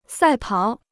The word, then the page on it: 赛跑: sài pǎo